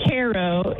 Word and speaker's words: Cairo: Over calling in from Cairo. Not that Cairo, Cairo, Ohio. Welcome. What's your question for Father? – Hi. Actually, it isn't from Cairo. It's pronounced Cairo in this part of the world.